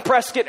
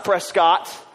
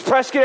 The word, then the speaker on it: Prescott: I was up in Prescott, Arizona, which that's how it's pronounced. It's not Prescott. If your mom's an English teacher, forgive me. It's Prescott, Arizona.